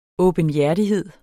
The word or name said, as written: åbenhjertighed